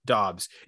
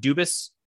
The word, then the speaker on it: Doubs: So go ahead, TJ, if you want to talk about Doubs. It is Doubs, right? It's not Dubis, as I've mistakenly pronounced at times.